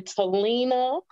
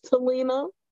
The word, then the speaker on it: Telina: You can type in Telina with a T and you will find me. That's Telina _ with a dot T.